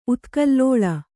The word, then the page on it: ಉತ್ಕಲ್ಲೋಲ: utkallōla